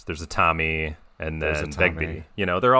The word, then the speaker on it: Begbie: Yeah, Robert Carlyle as Bigby. – Begbie.